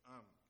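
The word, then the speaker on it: Ayhan: Ihan.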